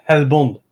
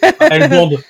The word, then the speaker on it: Hellbound: Ça se prononce Hellbound. Hellbound.